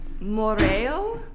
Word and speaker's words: Moiraio: I'm gonna butcher this, but Moreo, spelled Moiraio.